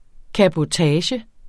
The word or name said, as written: cabotage